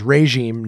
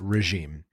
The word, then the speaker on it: regime: The first syllable is sounded as re. So it's regime, not regime.